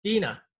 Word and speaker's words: Dina: Joining us today is our Canadian coach, Ontarian optimist, winning wellness instructor, maestro of movement, Deanna Trauntziopoulos. Did I pronounce that right? – Close. It's Dina, but good enough. – Dina.